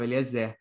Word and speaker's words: Eliezer: A sílaba forte está lá no final, Eliezer. E Eliezer.